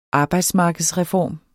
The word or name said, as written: arbejdsmarkedsreform